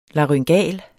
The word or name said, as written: laryngal